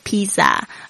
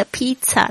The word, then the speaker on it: pizza: Number one，就是谁想去吃 pizza？哦，我不陪你了，我需要减肥。 Who wants to go eat pizza？ I'm out. I need to lose weight. 这边特别注意一下这个 pizza 的发音呢，有很多学生呢会念成这个 pizza，因为呢看到这个 zz 的这个声音嘛。其实呢这个 pizza 它是一个意大利文，所以呢它的念法呢是这个 tsss 的这个声音 pizza。